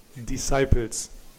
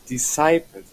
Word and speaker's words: Disciples: Wie spricht man es aus? – Disciples. – Disciples. Wieder was gelernt.